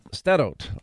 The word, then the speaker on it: Sderot: Did I say that right? – And Sderot. – Sderot.